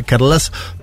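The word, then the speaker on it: Carles: sobre todo la formación Junts de Carlax Puigdemont, lo digo porque un oyente me dijo cómo se pronunciaba, Carlas Puigdemont.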